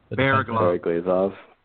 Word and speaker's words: Bereglazov: How do you say the Russian kid's name? – Bereglazov. – Bereglazov? – Bereglazov. – Neil Point. – Bear, and then glaze, like a donut.